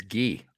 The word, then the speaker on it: Guy: In French Canada, it's Guy. – Guy Lafleur. – Like Guy Lafleur. – Is Guy like Guy? – Yes, it's spelled that way. G-U-Y.